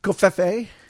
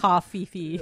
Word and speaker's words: covfefe: Covfefe, which is how I pronounce it. And if you pronounce it some other way, you're wrong. – I called it coffee-fee.